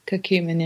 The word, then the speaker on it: curcumin: Curcumin, yeah.